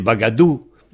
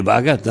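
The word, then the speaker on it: bagadoù: L'inconvénient d'avoir des mots bretons dans un contexte français, c'est que les gens prononcent à la française et ça francise les mots, comme les bagadous. dans la gadoue. Au lieu de dire les bagates, moi je dis les bagates.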